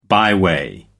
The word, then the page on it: byway: American English